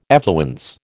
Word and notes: effluence: American English

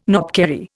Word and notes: knobkerrie: Indian

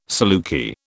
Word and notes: saluki: British English

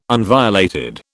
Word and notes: unviolated: British English

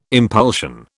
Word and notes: impulsion: British English

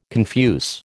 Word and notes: confuse: American English

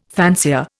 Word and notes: fancier: Indian

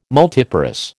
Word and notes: multiparous: American English

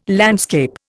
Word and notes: landscape: Indian

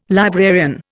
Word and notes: librarian: Indian

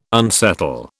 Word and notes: unsettle: British English

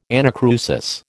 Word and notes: anacrusis: American English

